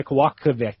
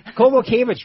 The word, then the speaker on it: Kowalkiewicz: Karolina Kowalkiewicz? – You don't know how to say it. Kowalkiewicz. – Kowalkiewicz, right.